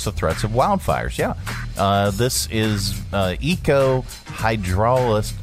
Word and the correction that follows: hydrologist: hydro hydrolist. Hydrolist, hydrologist. Oh, hydrologist. Okay, an eco hydrologist.